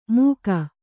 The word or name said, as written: ಮುಖ